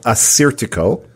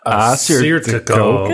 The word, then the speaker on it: Assyrtiko: It's called Assyrtiko. – Assyrtiko. – Good job. – Yes, very good.